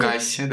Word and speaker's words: Pegasi: Пегаси, да.